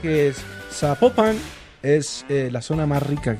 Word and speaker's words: Zapopan: Oiga, nos escriben, nos escriben los oyentes y que no se, no se pronuncia Zapopan, sino que se pronuncia Zapopan. – Ah, Zapopan.